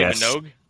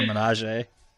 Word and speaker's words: Minogue: I think it's Kylie Minogue. – Oh, is that what it is? I always called her Menage.